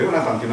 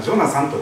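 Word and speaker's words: Jonathan: ヨナタンという名前です日本語にするとかっこ悪い名前かもしれませんでもあのカモメのジョナサンのジョナサンです英語で読むとヨナタンというのはジョナサンというふうに発音し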